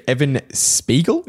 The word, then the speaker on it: Spiegel: Snapchat chief executive Evan Spiegel, is that how you pronounce his name? – Yeah.